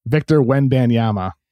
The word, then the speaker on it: Wembanyama: Victor Wembanyama.